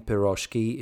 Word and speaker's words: piroshki: The stress in piroshki is properly placed on the last syllable. So they're saying it wrong.